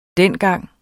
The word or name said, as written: dengang